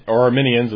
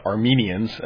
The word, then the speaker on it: Arminian: They would never have understood why people would say, we're not Calvinists or Arminians, as that one particular guy did not pronounce Arminian correctly. He said Armenians, as if you were from Armenia.